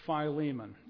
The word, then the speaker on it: Philemon: This is Philemon or Philemon, depending. I just opted to call this Philemon.